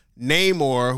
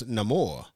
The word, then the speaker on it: Namor: First off, I've been not a fan, but I've been aware of Namor, who I thought that's how you pronounced it, but it's Namor. But I've, you know, Angela Bassett said Namor, and I felt validated because I've been reading the comics since I was a kid.